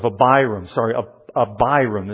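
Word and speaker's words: Abiram: It was at the cost of a Biram is how to pronounce it.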